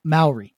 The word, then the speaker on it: Maori: New Zealand, Maori, right? – Yeah, that's right.